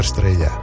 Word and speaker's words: Estrella: e esta grande música chama-se Super Estrella. Reparaste que eu disse com pronúncia estrella.